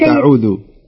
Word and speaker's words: أعوذ: لا ليست أعوذ. ليست أعوذ. كأنك الذال، الذال اللسان يخرج عند النطق بيخرج من الأسنان. لا يثبت في الأسنان أو يكون داخل الأسنان. لابد يخرج اللسان.